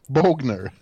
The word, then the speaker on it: Bogner: ja, ja, han heter inte Boschner heller som jag sa. Bogner.